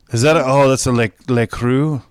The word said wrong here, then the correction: LaCroix: How do you say that? Le Creux? LaCroix. That's right.